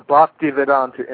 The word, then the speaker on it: Bhaktivedanta: The Bhaktivedanta Institute.